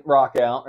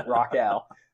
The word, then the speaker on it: Rachau: So my last name, Rockow, right? German, which I appreciate pronouncing that correctly. It's not the French Rochelle, but the nice German Rockow there.